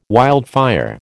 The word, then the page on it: wildfire: American English